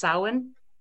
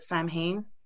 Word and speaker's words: Samhain: Samhain is Gaelic, and it's pronounced Samhain, but so many people pronounce it Samhain.